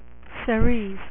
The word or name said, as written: Cerise